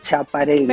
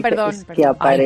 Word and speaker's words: Schiaparelli: No se dice Schiaparelli. – Perdón. – Schiaparelli. Ay, pobre Laurita, que no sabe italiano.